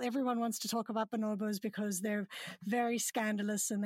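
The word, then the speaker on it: bonobo: And I heard you in a recent interview on Species Unite, another podcast, where you were speaking about chimpanzees and bonobos. Bonobos, mm-hmm. Sorry, right. – I completely mispronounced that.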